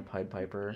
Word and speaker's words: Pied Piper: Maybe Pied Piper? Is that how you pronounce it? – Yeah, Pied Piper.